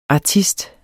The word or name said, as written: ARTISTERS